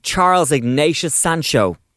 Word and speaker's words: Charles Ignatius Sancho: Can you tell us about The Secret Diaries of Charles Ignatius Sancho? – Well pronounced and well said.